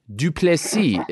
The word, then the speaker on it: du Plessis: I would say, first glance, I would say Dricus du Plessis is how I would pronounce it. How do you pronounce it? Duplessis is the correct pronunciation.